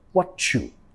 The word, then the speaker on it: what you: Nota con la pronunciación acá, I see what you, vamos a vincular metiendo sonido ch, ch, what you, what you.